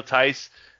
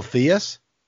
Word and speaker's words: Theis: I'll tell you what, anybody who says Theus, well, they're talking about Daniel Theis. That's your sign you're talking to an idiot. – Hold on. He calls him Daniel Theus? – Theus, yeah. – Bill Simmons doesn't even pronounce Daniel Theis's name correctly.